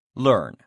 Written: learn

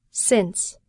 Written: since

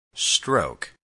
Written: stroke

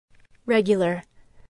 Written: regular

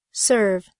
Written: serve

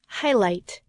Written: highlight

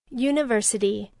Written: university

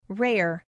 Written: rare

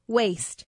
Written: waste

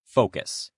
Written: focus

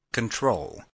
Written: control

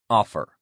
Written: offer

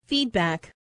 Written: feedback

